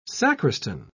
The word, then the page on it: sacristan: British English